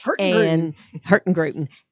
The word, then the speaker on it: Hurtigruten: If you do Norway, there's a cruise ship called, can never pronounce it, Hurtigruten. – Hurtigruten.